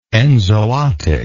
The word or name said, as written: enzootically